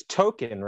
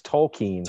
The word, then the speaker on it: Tolkien: Oh, it was Token, right? Yeah, didn't they say something about Token? – Yeah, his real name is... Well, they've been pronouncing his name wrong, or Stan has. – It's Tolkien.